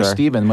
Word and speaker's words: Stephen: Anymore, Stephen, when they see it. Because Steph Curry, now I'm Stefan. I've been renamed. – Well, when people see my name, which happens to be spelled S-T-E-P-H-E-N.